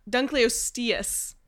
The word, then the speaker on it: Dunkleosteus: You can get like a Dunkleosaurus, which looks... Dunkleosteus. I pronounced it wrong.